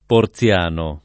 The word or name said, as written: Porziano